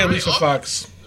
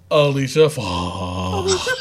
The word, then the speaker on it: Alicia Fox: Yeah, you said her name wrong. – I know. Sorry. Happy birthday, Alicia Fox.